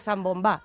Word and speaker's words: zambombá: la zambombada y entonces la zambombá. Esta es la explicación que da la RAE. Pero también hay que a una cosa. Pero la RAE le pone el acento en la última.